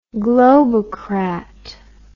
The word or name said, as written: globocrat